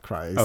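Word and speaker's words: Kryst: I thought it was Christ. – We'll go with Christ. – Okay. – Because it's spelled with a K.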